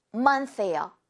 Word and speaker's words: month: 그래서 이렇게 1일부터 31일까지 있는데 이게 한 month예요. One month. 뭐예요? 바로 달, 월이라는 단어입니다. Month. 발음할 때 끝에 s 이렇게 소리 내어주세요.